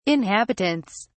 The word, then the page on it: inhabitants: British English